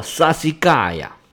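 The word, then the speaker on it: Sassicaia: 这个西施佳雅在意大利语里面读作 Sassigaia，就是小石头块儿的意思。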